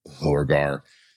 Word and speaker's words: Lorgar: And then Lorgar, and that's the only way to probably pronounce his name, by the way, is Lorgar, gets upset that his possessed brothers are getting killed and decides to jump in and fight.